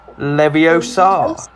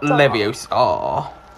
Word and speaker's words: Leviosa: Besides, you're saying it wrong. Okay. It's Leviosa, not Leviosa.